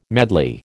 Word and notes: medley: American English

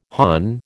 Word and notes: hon: American English

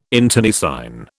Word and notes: internecine: British English

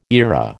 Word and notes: era: American English

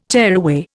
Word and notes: tearaway: Indian

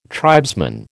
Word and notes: tribesman: American English

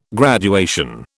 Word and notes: graduation: British English

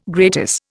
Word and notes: gratis: Indian

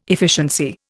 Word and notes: efficiency: Indian

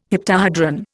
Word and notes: heptahedron: Indian

